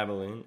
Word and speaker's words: Abilene: Albine Christian. Mm-hmm. Did I pronounce that correctly? – Yeah, Abilene, yeah.